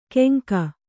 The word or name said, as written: ಕೆಂಕ